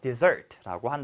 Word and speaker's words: dessert: 그래서 발음할 때 디저트는 뒤에 강세해서 dessert라고 한다.